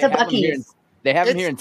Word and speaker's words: Buc'ees: Is it Buc'ees? – Yeah, baby, Buc'ees.